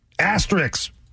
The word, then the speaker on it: asterisk: Asterix. – There's an S before the K.